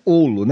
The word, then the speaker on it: Hollo: É, na verdade é Olo, né? Porque Olo. É de, de, origem húngara. Tá. Né? E aqui no Brasil nós não pronunciamos o R, né? Se fosse de origem italiana ou árabe seria Rolo, que aí você pronuncia Vou só com o H microfone R.